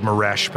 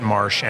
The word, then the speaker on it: Maresh: Um, looks like Maresh, but the family pronounces it Marsh.